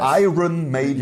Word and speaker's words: Iron Maiden: Entschuldigung, ich hab's total falsch ausgesprochen. Iron Maiden.